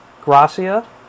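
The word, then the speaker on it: Grassia: So again, my name is Michael Gracia. It's spelled G-R-A, S as in Sam, S as in Sam, I-A.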